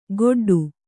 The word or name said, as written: ಗೊಡ್ಡು